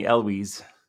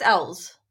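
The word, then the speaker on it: Elwes: 2019's Black Christmas, directed by Sophia Tocal, starring Imogene Poots, Alice Shannon, and Lily Donahue, featuring a guest appearance by Carrie Ells. I said that right? – Yeah. – My whole life I've been saying Eloise. – No, I think it's Ells.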